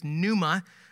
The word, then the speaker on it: pneuma: which in Greek is pneuma. It starts with a silent P, okay? Pneuma.